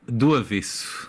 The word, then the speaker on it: Do Avesso: Do avesso.